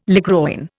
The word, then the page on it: ligroin: Indian